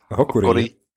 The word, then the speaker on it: Hokori: Absolut akzeptabel. Ja, Hokori wäre es. – Hokori.